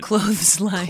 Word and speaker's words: clotheslines: But you want to honor the TH in it. – Clotheslines. – Clotheslines. – Clotheslines. – Clotheslines. – Because would you say, hey, pick clothes up your clothes. You say your clothes. – Your clothes. We say it like right. You say clothing.